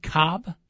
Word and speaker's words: Kolb: Wait, his last name is spelled K-O-L-B. Are you telling me the L is silent? – Yes, Coach.